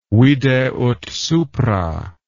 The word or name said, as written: vide ut supra